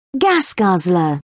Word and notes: gas guzzler: British English

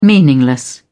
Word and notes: meaningless: British English

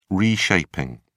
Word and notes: reshaping: British English

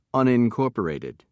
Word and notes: unincorporated: American English

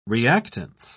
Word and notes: reactance: British English

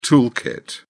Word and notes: tool kit: British English

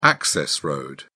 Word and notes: access road: British English

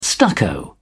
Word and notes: stucco: British English

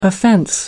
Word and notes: offense: American English

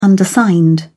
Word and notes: undersigned: British English

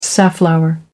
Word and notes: safflower: American English

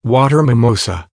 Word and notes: water mimosa: American English

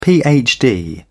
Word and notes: phd: British English

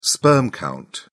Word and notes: sperm count: British English

